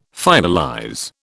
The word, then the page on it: finalize: British English